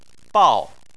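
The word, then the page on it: bao: tone 4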